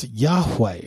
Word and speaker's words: Yahweh: It's actually the tetragrammaton that's pronounced as Yahweh, approximately.